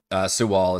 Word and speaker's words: Siwal: But the idea is, you said Siwal? How do I pronounce it? – Siwal is how I've been doing it, yeah.